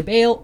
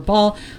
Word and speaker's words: Baal: It's just for those of you listening, it's spelled B-A-A-L, right? So either Baal or Baal. Baal, I don't care.